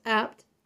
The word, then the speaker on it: Abt: That's A as in apple, B as in boy, T as in Thomas, but pronounced apt.